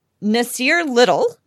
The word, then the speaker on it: Nasir Little: The Blazers this week or last week, depends on how you look at it, uh, selected Nasir Little.